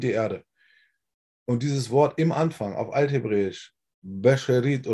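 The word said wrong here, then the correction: Bereshit (בראשית): oder wie das heißt, ich kann es leider nicht genau aussprechen. Bereshit. Genau. Ah, wie nochmal? – Bereshit. – Bereshit, genau, Bereshit. Dieses Wort hat auch eine piktografische Bedeutung,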